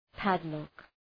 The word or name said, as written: padlock